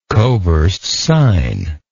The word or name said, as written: coversed sine